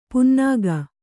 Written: ಪುನ್ನಾಗ

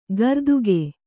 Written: ಗರ್ದುಗೆ

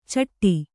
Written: ಚಿಟ್ಟು

